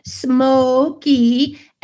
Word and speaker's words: Smokey: Because the E goes over, makes the O say its name, Smokey, and then the Y at the end makes the long E.